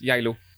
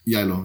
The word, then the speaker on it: Geilo: I like the German way of spelling of it because it's like written Geilo. – And that's close to how it's pronounced, Yalo. – Yalo, yeah.